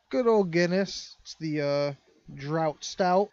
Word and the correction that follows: draught: I believe that's pronounced draft.